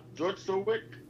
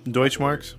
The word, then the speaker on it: Deutschmark: The civil case concluded, and the court ordered Mercer to pay Michael's 7,000 Deutschmark money. What's the word? – Deutschmarks. – How to pronounce it. There you go, Deutschmark.